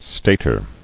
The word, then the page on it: Stater: stat·er